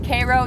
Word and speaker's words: Cairo: Cairo, yes, they pronounce it like the syrup.